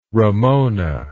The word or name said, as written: Ramona